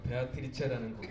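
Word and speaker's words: Beatrice: 그래서 영어 발음 나는대로 비트 라이스. 아, 이게 박자 감각 있는 쌀인가 보다. 이렇게 생각하고 얘기했는데 나중에 저한테 이렇게 얘기를 해주시더라고요. 베아트리체라는 곡이라고. 예. 죄송해요.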